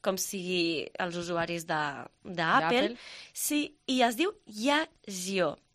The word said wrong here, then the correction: Yazio: no sé com es pronuncia, Yazio, és Yazio. És una aplicació que, a banda de sincronitzar doncs, tota l'activitat i els teus registres alimentaris, bàsicament serveix per això, eh?